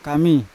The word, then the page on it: kami: Yawa